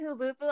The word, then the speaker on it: HHFUBF: It's Hubufu. – Well, thank you, Hubufu. I can't believe we've been pronouncing it wrong this entire time.